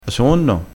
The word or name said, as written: ha